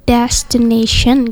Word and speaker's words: destination: Kalau destination sendiri mungkin kayak destination gitu ya. – Okay. It's not dash gitu ya, tapi lebih kayak antara A dan E gitu.